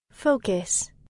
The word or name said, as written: focus